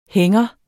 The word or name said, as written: hænger